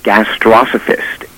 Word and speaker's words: gastrosophist: I found it in a 19th century diet book, and I think that the pronunciation is gastrosophist.